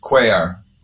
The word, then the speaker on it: Cuellar: Cuellar.